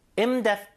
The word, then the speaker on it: in-depth: in-depth test. 자, 요거는 in-depth test 해서 약간 TH 발음이 거의 없는 것처럼 발음하셔도 돼요.